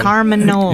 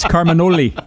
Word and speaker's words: Carminoli: You think that's a silent E on the end. Actually, it's an accented E. It's Carminoli.